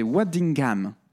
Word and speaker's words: Waddingham: Waddingham.